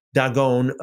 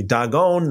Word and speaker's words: Dagon: Dagon, by the way, Dag, pronounced as dog in Hebrew, means whole thing.